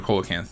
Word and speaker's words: coelacanth: Is it called coelacanth or coelacanth? – Coelacanth.